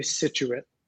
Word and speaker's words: Scituate: Oh, did I say it wrong? – It's actually Scituate.